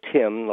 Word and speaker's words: Tymn: Yeah, well, if you just put my name, Michael Tymn,